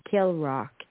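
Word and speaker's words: Kilravock: A young man to Killrock Castle, pronounced Killrock, Inverness, Scotland.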